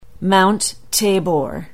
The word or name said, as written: Mount Tabor